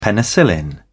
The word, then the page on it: penicillin: British English